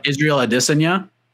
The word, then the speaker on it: Israel Adesanya: Someone hit me up on Twitter because I said Israel Adesanya, which is the correct pronunciation.